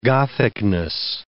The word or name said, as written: Gothicness